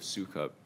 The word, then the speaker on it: Sukup: And it looks like the way the parties discuss it, the key precedential case that gets close to this issue is Sukup, if I'm pronouncing that right. – That's how I pronounce it. – Okay, so my question about your view of Sukup is that you seem to rely most heavily on the syllabus point, right? – Correct.